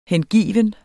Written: hengiven

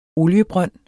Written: oliebrønd

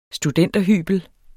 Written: studenterhybel

